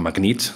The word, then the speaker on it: Magnet: Wer ich bin, hast du gerade schon gesagt, mein Name Martin Magnet oder Magnet. Das kann jeder sagen, wie er will. Da gibt es alle Formen und Farben der Aussprache.